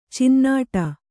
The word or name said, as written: ಚುನ್ನಾಟ